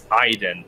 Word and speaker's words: Aiden: Aiden, it's Aiden, like the I. E-Y-E.